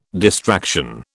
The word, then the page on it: distraction: British English